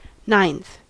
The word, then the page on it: ninth: American English